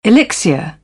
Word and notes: elixir: British English; əɹ

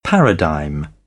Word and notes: paradigm: British English; æ

